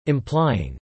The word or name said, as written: implying